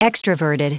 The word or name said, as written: extraverted